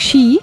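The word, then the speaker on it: щи (四): В Китае и Японии несчастливыми считаются числа 4 и 9, поскольку 4 произносится по-японски как «щи», что созвучно слову «смерть», а 9 «кю» напоминает слово «боль».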